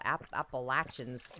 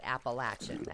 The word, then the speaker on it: Appalachian: It's a regionalism because the actual Appalachians their dialect, they pronounce it Appalachian as in latch. People who live outside say Appalachian, so I don't know why.